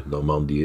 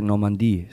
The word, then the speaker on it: Normandië: Ja, de klemtoon ligt andere... op de A. Normandië. Ik vind het mooi zoals je het uitspreekt. – Ja, dat is Duits, want je zei Normandie, zeg je in het Duits.